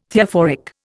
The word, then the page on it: Theophoric: Indian